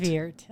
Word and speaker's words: de Weerd: And I wish that I put money down on it now, but it's Tammy de Weerd, correct? – De Weerd. Uh-huh.